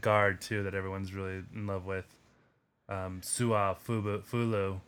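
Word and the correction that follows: Su'a-Filo: How do you pronounce his name? – Sua Filo. I would say Sua Filo, but I'm not sure. – Sua Filo. Xavier Sua Filo.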